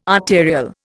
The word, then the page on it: arterial: Indian